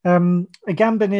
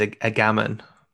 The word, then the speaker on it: Agamben: So Giorgio Agamben is... – I think you're mispronouncing the surname. It's Agamben.